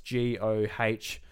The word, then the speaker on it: Go: So in Japanese, it's basically, it's pronounced G-O-H, G-O. So Gugo. – Gogo. – Would you just say Gogo? We'll say Gogo. That sounds more normal.